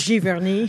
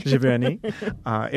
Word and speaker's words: Giverny: So for this show, MOA, it's called Giverny of the Midwest. If you say it American-wise, I'm not going to try to pronounce the French. – It was Giverny. – Giverny.